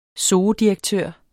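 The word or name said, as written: zoodirektør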